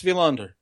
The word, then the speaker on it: Wilander: It's spelled M-A-T-S. – Yeah, Mats Wilander. – All right. – Yeah, the W that's pronounced V is a trick.